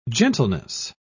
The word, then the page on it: gentleness: British English